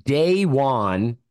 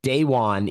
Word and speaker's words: Da'Wain: So they get – it's pronounced Day One. I've heard him say it like that, and also on the Virginia Tech Media Guide. Daywon, even though it kind of looks like Dwayne to me.